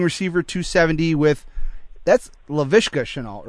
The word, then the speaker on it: Shenault: I believe Shenault is how you pronounce that. – I checked it, yep.